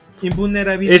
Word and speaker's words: invulnerabilidad: Eh, inmunerabilidad, ¿sí se dice así? Invulnerabilidad.